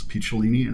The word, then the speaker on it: Picciolini: And I was afraid to tell him because my last name is hard to pronounce, Picciolini, and it was mangled by every bully that I'd ever met. It unfortunately rhymes with weenie.